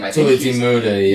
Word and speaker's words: Tulathimutte: So Tony Tiamatte, I don't know how to say his name. – Tulathimutte, yeah.